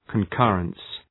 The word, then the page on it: concurrence: kən’kɜ:rəns, kən’kʌrəns